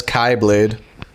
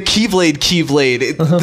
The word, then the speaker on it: Keyblade: Someone in the department, in Square Enix department, was like, refer to that as Kyblade. – Yes. – The Greek pronunciation.